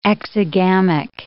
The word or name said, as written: exogamic